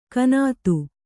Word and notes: ಗುಣ್ಣಿತ್ತು: Kannada

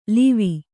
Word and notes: ಲಾವ: lāva

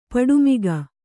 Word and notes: ಪಡುಮಿಗ: paḍu miga

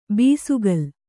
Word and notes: ಬೆಸಕೋಲು: besakōlu

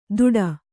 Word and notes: ದುಡ: duḍa

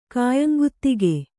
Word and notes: ಖಾಯಂಗುತ್ತಿಗೆ: kannada

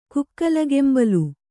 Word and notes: ಕುಕ್ಕಲಗೆಂಬಲು: kukkalagembalu; dial.

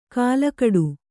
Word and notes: ಕಲಕಾಡು: Kannada

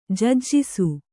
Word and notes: ಜಜ್ಜಿಸು: Kannada

